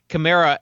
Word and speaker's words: Kamara: Kamara is the correct pronunciation.